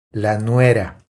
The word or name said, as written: La nuera